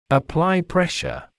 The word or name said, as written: apply pressure